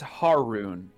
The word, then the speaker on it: Haroon: doing the podcast like editing and stuff, I realized the very first session I said my own last name wrong. – How'd you say it? – I said it's Haroon.